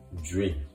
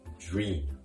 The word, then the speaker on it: dream: もう一度発音すると、夢は英語で dream ですね。 R の発音に気をつけましょう。 dream, dream 小さくうこういうような形で dream, dream と発音しましょう。